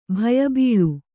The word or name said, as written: ಭಯಬೀಳು